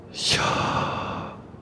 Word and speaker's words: Yahweh: So it sounds like this. The more correct way to pronounce it is this. Yahweh. Yahweh.